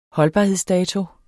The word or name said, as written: holdbarhedsdato